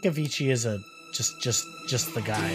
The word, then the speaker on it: Avicii: It's spelled A-V-I-C-I-I, if you didn't know.